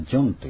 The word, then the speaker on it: John: それでドイツ語なんかの発音ではヨハンネス、ヨハンとかヨハンの女子でしたらね、ヨハンナとかヨハンネス、ヨハンネス・ブラームスとかいうのもこういう名前から来ていますが、英語では J をジと読みますので、ドイツ語ではJ をヤ行で読むわけですね。ですから、英語ではジョンという発音なんです。